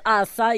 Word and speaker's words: Asa: We've got Asha from Nigeria. – Yes. – Pronounce Asa.